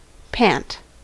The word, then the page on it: pant: American English